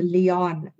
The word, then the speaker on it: Liane: She corrected us, and it's a Liane Moriarty. Oh. – After many, many years, of being Liane's editor, Amy finally realized she was saying Liane's name wrong.